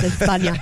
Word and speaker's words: España: You know, it's actually pronounced España.